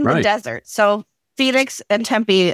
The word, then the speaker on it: Tempe: Tempe. – Tempe.